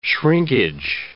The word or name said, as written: shrinkage